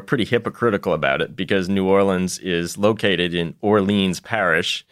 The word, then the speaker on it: New Orleans: Nolens is just a jokey touristy thing. So the only acceptable ways to say it are New Orleans or the most accurate way, which is the extra syllable of New Orleans.